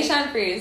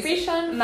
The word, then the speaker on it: Bichon Frise: Is it Bichon Frise? – Frise, okay. Bichon Frise. – Bichon Frise.